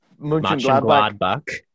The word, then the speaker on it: Mönchengladbach: So we've got Borussia Mönch... I knew I was going to butcher this. – Mönchengladbach. Yeah.